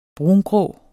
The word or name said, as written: brungrå